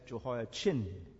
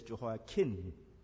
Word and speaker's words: Jehoiakim: Some people pronounce Jehoiakim as Jehoiachin, C-H-I-N. While in the Hebrew, it's Jehoiakim.